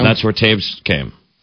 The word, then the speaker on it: Toews: I called him Toes for like 2 straight years. – I'm not kidding. Like, you look at that and you're like, there's nothing to indicate that should be pronounced Toews.